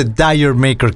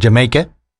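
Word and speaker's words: D'yer Mak'er: donde viene aquello de Dire Maker, que deberíamos de pronunciarlo o tratar de pronunciarlo de otra manera, dando a entender que en lugar de Dire Maker se está diciendo algo así como Jamaica, por más raro que suene, o Did You Maker, o algo así como ¿Se lo hiciste?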